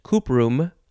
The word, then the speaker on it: cuprum: That is because it is for the Latin word of copper, which is, I guess you'd pronounce it cuprum, C-U-P-R-U-M, cuprum.